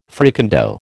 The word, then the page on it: fricandeau: American English